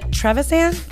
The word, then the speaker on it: Trevisan: We have Miss Amanda Trevisan.